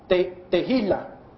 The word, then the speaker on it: tehillah: La tercera palabra, alabar, también en la palabra griega, viene de la palabra te, como Tony, T-E-H-I-L-L-A-H, que se pronuncia te-il-la, te-jila, te-jila, te-jila, es otra palabra que simplemente quiere decir alabar, y esa palabra te-jila quiere decir loar, exaltar con un canto.